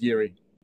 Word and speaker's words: Jiri: You don't actually want to know what it sounds like in Czech because you wouldn't be able to pronounce it. So let's stick with Yuri.